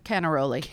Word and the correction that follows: Carnaroli: No, Carnaroli. – Carnaroli. You're faking me out. – Sorry. – Canaroli, Carnaroli. – And we're not rolling our R's.